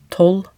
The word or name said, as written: tolv